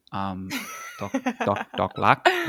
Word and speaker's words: Dok Rak: And funny to hear everyone pronounce it so differently, like Dok Rak, Dok Rak, Dok Rak. Dok Rak, which is how I think it's actually more close in Thai.